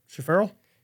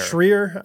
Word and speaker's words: Schreier: Schaferl? – No. Schreer. I'm going to guess Schreer. – Schreer. – S-C-H-R-E-I-E-R. – Got to be Schreier. Give me Schreier.